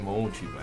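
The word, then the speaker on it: Marisa Monte: com'è che si pronuncia? – Sì, Monchi.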